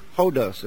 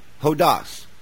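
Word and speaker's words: Hodos (ὁδός): It's the Greek word hodos. Am I pronouncing that right? Hodos. Hodos. And the definition of it is properly a way, a traveled way, a road, a traveler's way, journey, traveling.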